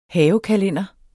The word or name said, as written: havekalender